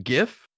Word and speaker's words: GIF: When they found out that was actually how it's pronounced by the creator, I'm like, yeah, JIF.